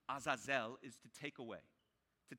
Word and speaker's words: Azazel: Now, the Hebrew word for the scapegoat in this particular context is the word Azazel. Three syllables, Azazel.